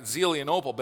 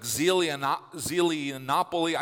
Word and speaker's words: Zelianople: It's Zelianople, right? Zelianople. But for me, it was like Zelianopoly.